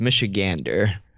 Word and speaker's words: Michigander: So for, I don't know what you call a native Michigan, someone from, is it Michigana? Is that the correct term for a native from Michigan? – Close, actually. It's pronounced Michigander.